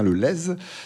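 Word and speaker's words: Lez: On prononce bien le Z, je crois. – Tout à fait. – On dit bien le Lèze, qui est un cours d'eau, le Lèze.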